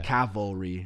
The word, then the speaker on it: Cavalry: Oh man, Cal Calvary FC from Calgary. Who the fuck named that? No one can say that. – Cavalry. – Cavalry from Calgary. Yes. No matter how many times you say that right, you'll say it wrong.